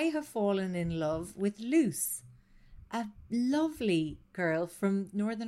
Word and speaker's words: Luz: So it's spelled L-U-Z. Nubile. Put it away, Jen Rogan. – Just saying. – Her name is spelled L-U-Z, but her Instagram informs me that it's pronounced Luce. Luce.